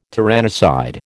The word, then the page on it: tyrannicide: American English